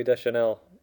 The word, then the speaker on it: Deschanel: Oh, Zoe Deschanel.